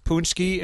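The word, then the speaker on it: Pączki: It's also National Punchki Day, spelled like Pachki, pronounced Punchki.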